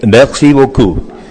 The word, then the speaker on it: merci beaucoup: Verónica, merci beaucoup.